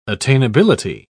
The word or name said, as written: attainability